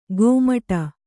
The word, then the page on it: ಗೋಮಟ: gōmaṭa